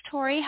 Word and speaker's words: Tori: This is Tori.